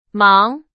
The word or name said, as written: 忙